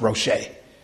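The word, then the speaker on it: Roshe (ראש): The word in the Hebrew is Rosh. R-O-S-H is spelled R-O-S-H and it's pronounced Roshe.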